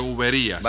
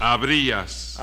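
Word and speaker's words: habrías: Y además no se dice uberías. – Vamos, ¿y cómo se dice entonces? – Abrías.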